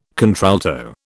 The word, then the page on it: contralto: British English